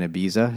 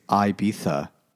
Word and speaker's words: Ibiza: hanging out in Ibiza. It's like, actually, it's Ibiza. No, dickhead. That's not how they say it either.